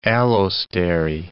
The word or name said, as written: allostery